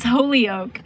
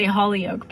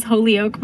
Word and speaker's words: Holyoke: It's Holyoke. Some people say Holyoke, but it is Holyoke, Massachusetts.